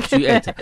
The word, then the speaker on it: G8: 常常这个要提醒，这个要发音就是要用英文的发音的 G8 好啦，那个问题是说，你刚刚有提到一点啦，就是说这个印度这些国家他们也是很想去做这些事情。